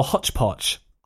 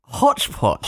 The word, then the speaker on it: hotchpotch: So would you pronounce that hodgepodge or hotchpotch? – Hotchpotch. – Hotchpotch. Well, may you sneer at that. But just know that that is the traditional British variant of the phrase.